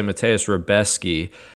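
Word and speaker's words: Mateusz Rębecki: Next, we're going to look at Mateus Rebeski is how they pronounced it in the broadcast. So we'll say Mateus Rebeski.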